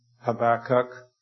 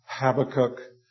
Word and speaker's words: Habakkuk: This morning I'd like to draw your attention to the book of Habakkuk, or Habakkuk. Depends on the shul that you're from. We have several pronunciations for that word.